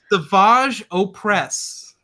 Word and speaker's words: Savage Opress: Is that how you're supposed to pronounce it, Savage? – Yeah, really? – It's Savage Opress.